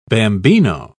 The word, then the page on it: bambino: British English